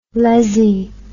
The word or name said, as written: lezzie